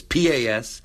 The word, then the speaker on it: Pasqualino: And I say her last name wrong, which is embarrassing. Pasqualino. I said it wrong, but it's Pasqualino.